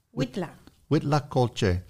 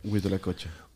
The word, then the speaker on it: huitlacoche: How do you say it? Huitlacoche? Huitlacoche. – Huitlacoche.